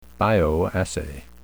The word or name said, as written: bioassay